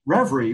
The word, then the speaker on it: Revry: So Reverie, which I'm going to put up the slides and the links anyway, but it's spelled R-E-V-R-Y.